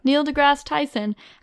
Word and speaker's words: Neil deGrasse Tyson: in which he discusses astrophysicist Neil deGrasse Tyson. Is that how you say it? – Yeah. – Neil deGrasse Tyson, after the two had a Twitter battle over the sphericalness of the planet.